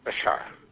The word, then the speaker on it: Bashar: Bashar. – Bashar. – B-A-S-H-A-R.